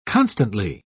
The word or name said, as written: constantly